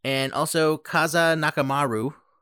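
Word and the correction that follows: Kazuo Nakamura: Nope. Kazo Nakamura. Yeah. Kazo Nakamura.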